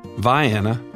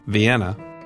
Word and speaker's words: Vienna: But like many Georgia towns, Vienna, not Vienna, Cairo, not Cairo, it's pronounced a distinctly southern way, Bremen, in this case.